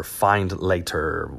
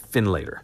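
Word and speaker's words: Findlater: And the place was called Wright's Finlander, or find later, whatever it was. All right. So it's Wrights, W-R-I-G-H-T-S, and then F-I-N-D-L-A-T-E-R. I want to say they pronounce it Finlater.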